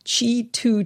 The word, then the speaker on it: Qi: Qi 2 chargers. That's spelled Q-I and the number 2 slapped right up against the word Qi.